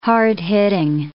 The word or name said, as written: hard-hitting